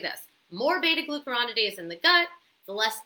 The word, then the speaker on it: beta glucuronidase: So, this estrobolome microbes basically produce something called, let's see if I pronounce it right, beta glucuronidase.